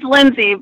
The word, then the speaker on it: Lindsay: It's Lindsay, but it's spelt with an A-Y.